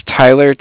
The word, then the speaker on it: Tyler: Yeah, my name is Tyler, T-Y-L-E-R, and I'm calling with Cardiff. It's spelled C-A-R-D-I-F-F, as in Frank Frank.